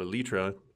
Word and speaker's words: elytra: And if you think about like a ladybug or a ladybird beetle, the elytra that you mentioned, or as you pronounce it, elytra, there's many different pronunciations.